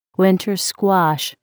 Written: Winter Squash

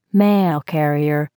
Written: mail carrier

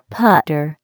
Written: putter

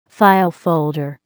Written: file folder

